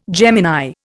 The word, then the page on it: Gemini: Indian